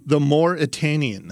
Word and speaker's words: The Mauritanian: The movie is The Mauritanian. – The Mauritanian.